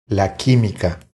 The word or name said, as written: La quimica